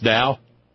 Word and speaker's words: Tao: It's like TAO is not tau. It's dow. – Exactly. – Okay. Well, I'm home with it now then. – Yes. Yes. There's a certain dow of the word duh.